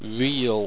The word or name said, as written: wriel